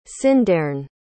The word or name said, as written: cyndeyrn